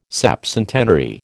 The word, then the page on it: septcentenary: American English